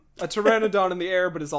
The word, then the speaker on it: Pteranodon: Paternodon? – Pteranodon? – Oh, the P is silent.